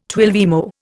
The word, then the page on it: twelvemo: Indian